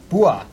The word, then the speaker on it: Bua: Next up is Nicholas Burt. – It's Bua. – Bua. – Nicholas Bua. – Yeah, it's a short Sicilian name.